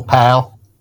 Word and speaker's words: Powell: Powell. You gotta say it like that. – You have to say like a local Powell.